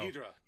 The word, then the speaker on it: Ghidorah: I at first I thought this was going to be like my second favorite Godzilla movie after the first one, and Godir and Godira. Am I pronouncing that right? What? G- No. Ghidra. Ghidra. – Sorry. Ghidra. Damn.